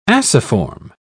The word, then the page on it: aciform: British English